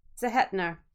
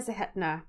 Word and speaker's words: Zehetner: Lucas Haas, and Nora Zehetner. Sorry, butchered that name. Nora Zehetner.